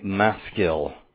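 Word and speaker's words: Maskil (מַשְׂכִּיל): This is usually transliterated as a maskil. Maskil is the way you would actually pronounce it, but the Hebrew is a participle of a word that means to.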